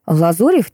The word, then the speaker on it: Лазурев: Лазурев — тихий, уютный морской городок, ударение, кстати, на «о».